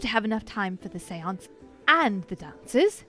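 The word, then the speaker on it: séance: I don't know how full your dance card is, but we've got a the family's got an invitation to this Cianci thing. – Oh, seance! There's a seance here. – Oh, that's how you say it.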